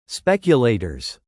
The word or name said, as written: speculators